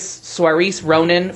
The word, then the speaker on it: Saoirse Ronan: I never pronounce her name right, but I think it's Saoirse Ronan for Brooklyn.